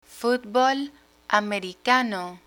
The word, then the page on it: fútbol americano: Spanish